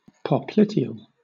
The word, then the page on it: popliteal: British English